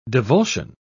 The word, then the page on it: divulsion: British English